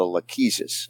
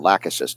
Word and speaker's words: lachesis: And then I follow it up with a lachesis or lachesis, depending on how you say it, L-A-C-H-E-S-I-S.